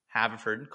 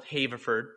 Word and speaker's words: Haverford: I remember even when we first made Nationals, and to be fair, he did mispronounce Haverford, called it Haverford, but I'll forgive him for that.